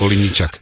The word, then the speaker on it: Olenitschak: Jij zegt Olenitschak. Dat is Olenitschak. De uitspraak. – Olenitschak.